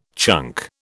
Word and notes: chunk: British English

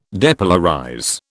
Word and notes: depolarize: British English

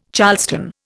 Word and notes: charleston: Indian

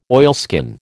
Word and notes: oilskin: American English

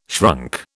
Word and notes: shrunk: British English

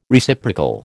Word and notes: Reciprocal: American English

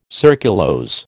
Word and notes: surculose: American English

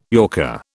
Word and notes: yorker: British English

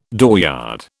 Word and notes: dooryard: British English

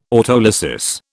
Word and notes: autolysis: British English

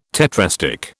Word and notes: tetrastich: British English

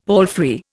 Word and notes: palfrey: Indian

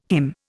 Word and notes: HIM: Indian

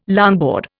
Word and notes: longboard: Indian

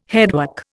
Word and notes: headwork: Indian

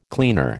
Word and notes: cleaner: American English

